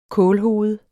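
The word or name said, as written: kålhoved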